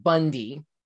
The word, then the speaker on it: Bounde: She revealed, quote, the issue is that our last name is spelled B-O-U-N-D-E, pronounced Bundy.